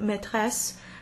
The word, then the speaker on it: maîtresse: But the circonflexe over the I in maîtresse doesn't change the pronunciation at all, it's still just an open A.